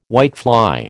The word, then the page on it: whitefly: American English